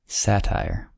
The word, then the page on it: satire: American English